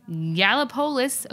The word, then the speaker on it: Gallipolis: Bob Evans himself in Gallipolis, Ohio. That is how it's pronounced. I'm 90% sure because I remember that town from when I lived next door to it in Indiana.